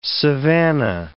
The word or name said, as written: savannah